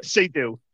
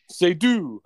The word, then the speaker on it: Seydoux: The Bond girl is Leah Skydux. I'm good at these games. How do you say it? – Seydoux. – Oh, jeez. – It's okay. French, man. Come on. – Seydoux.